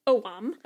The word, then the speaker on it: Ogham: So anyways, I did some quick Googling, and what I found is that there is a Celtic alphabet of Owam, spelled O-G-H-A-M, but apparently pronounced Owam.